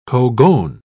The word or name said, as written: cogon